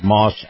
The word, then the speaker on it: Maus: is brought to you by our friends at Moss Acura. Moss is spelled M-A-U-S.